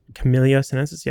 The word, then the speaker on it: Camellia sinensis: Camilla sinensis. – Okay. – I'm probably butchering it. Camellia sinensis.